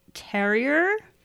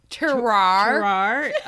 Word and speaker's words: terroir: From ground to glass, we're proud to say it's a true taste of Rogue Terrier. – Terroir.